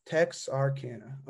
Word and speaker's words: Texarkana: Am I saying it right? Texarkana. – Texarkana.